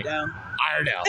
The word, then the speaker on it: Iredell: Idle County. I don't even know. It's Idle County. I don't know what county. Iredell. That's how you pronounce it.